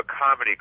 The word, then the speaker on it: Comity: There's a part of the Constitution, it's called the Comity Clause, but it's spelled C-O-M-I-T-Y.